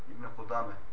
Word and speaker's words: İbn-i Kudame: İbn'i bir daha oku. – İbn-i Kudame. – İki tane D yok. Ben normalde yanlış telaffuz ediyorum. Şeyim dönmediğinden dolayı. Yani normalde tek D ile okuyacaksınız. Çift D ile değil, tamam mı?